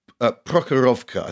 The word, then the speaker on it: Prokhorovka: Prokhorovka, I think is how you pronounce it.